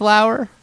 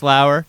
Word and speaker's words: Lower: Anybody that hasn't watched Justin Lauer, I think it's Lauer, right? – Yeah. – Spelled lower, but it's pronounced Lauer.